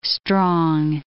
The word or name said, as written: strong